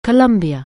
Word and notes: Colombia: anglais

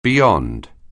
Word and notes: beyond: anglais

